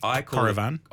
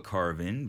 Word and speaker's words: Coravin: What's the correct way to say it? – Coravin. – I call it Coravin because, you know, we're Australian. Yeah. A lot of people do put a little bit of a French twang on it and go Coravin.